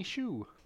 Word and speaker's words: Hsu: Miss Stephanie Hsu. – Elizabeth Shue's daughter? – No. It's sure actually HSU. – Okay. – But it's pronounced Hsu. Yeah. I had to look it up.